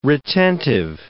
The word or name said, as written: retentive